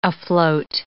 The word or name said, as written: afloat